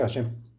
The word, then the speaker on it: Hashem: Y se deberá meditar el santo nombre de Hashem, que es Hei Shin Mem, y se pronuncia Hashem.